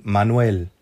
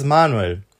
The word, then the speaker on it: Manuel: Also man, genau, also da haben wir den Akzent auf der letzten Silbe, wohingegen in Manuel. Manuel, ja. genau. Ja. Aber in Italien und in Deutschland ist es Manuel.